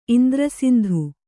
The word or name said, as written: ಇಂದ್ರಸಿಂಧು